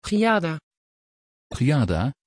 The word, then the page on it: giada: Dutch